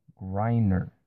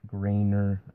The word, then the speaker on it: Griner: So, her actual name is Brittany Griner, not Grainer. I said Grainer. My mistake.